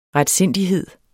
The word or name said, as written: retsindighed